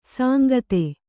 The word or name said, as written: ಸಾಂಗತೆ